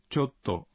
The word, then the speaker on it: chotto: Al pronunciar chotto, recuerden contener la respiración un instante después de cho.